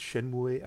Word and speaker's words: Shenmue: Spreek je het Shenmue uit? – Zo spreek ik het uit.